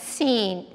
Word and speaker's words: Sin: Sin there, it's actually, the word is pronounced seen. That doesn't mean sin, like I said a cuss word or I robbed a bank.